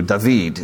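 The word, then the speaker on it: David (דוד): David, his name in Hebrew, David, is how you pronounce it, David.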